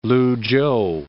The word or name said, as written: Luchow